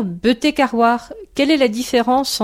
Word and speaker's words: betek: Donc, B-T-E-K, B-E-T-E-K, un petit G après, ça indique la prononciation. Ou bien B-E-T-E-G. Il y a les deux façons d'écrire, puisque tout le monde n'est pas d'accord.